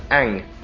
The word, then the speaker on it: Aang: Okay, yeah, Aang. To rhyme with the third syllable in boomerang.